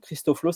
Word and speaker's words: Christoflo: Donc Christoflo, c'est comme Christ au flot.